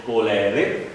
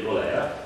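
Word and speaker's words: cholerae: Allora, la specie più importante, più nota, è quella del Vibrio colere, si dice colere, perché il genitivo è del colera, anche se molti lo pronunciano Vibrio colera, non è che è importante che voi sappiate che si chiama così.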